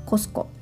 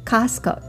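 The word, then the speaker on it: Costco: すはいそして、えこの収録を終えたらですねもう一度買い物に行きます今度は別の場所、うん、コスコですねあ日本だとコストコっていうようなんですけれどもこちらではコスコ T を発音しませんもっと英語風に言うとカスコで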